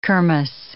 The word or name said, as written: kermis